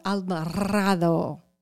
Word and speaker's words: Alvarado: Say your name with the rolling R, and I'll give you the... – Okay. – I'm... – Ready? I'm Lisa Alvarado.